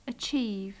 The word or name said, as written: achieve